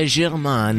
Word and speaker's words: Saint-Germain: Credo sia Germain, la pronuncia, Santo Germano.